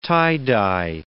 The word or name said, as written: tie-dye